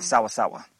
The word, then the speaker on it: sawa-sawa: Oh, so how do you say it? – Sawa-sawa. – Sawa-sawa. – S-A-W-A-S-A-W-A. – Nice. – And it's sawa-sawa because it's bitter. – Oh, sour. But it's pronounced or said sawa-sawa.